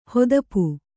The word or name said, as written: ಹೊದಪು